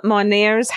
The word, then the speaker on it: Monnier: I speak of a spinster who was locked up in Madame Monet's house. Monnier's? M-O-N-N-I-E-R-S. – Monnier. Yeah, I mean, yeah.